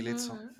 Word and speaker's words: Lizzo: Luego también se me ocurre la cantante Lizzo, supongo que se pronuncia así. – Sí, Lizzo.